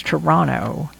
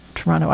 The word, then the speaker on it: Toronto: Actually, I heard that you're not supposed to pronounce it with the second T. It's Toronto. Have you heard that? Toronto.